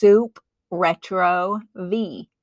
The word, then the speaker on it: Supretrovie: It's Supretrovie, like supernatural, retro, which is a French word meaning past, and vie, V-I-E, actually means life.